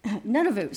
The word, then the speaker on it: Nunavut: Nunavut. Did I say that right? Nunavut? Close enough. Nunavut. Sorry, I missed the N.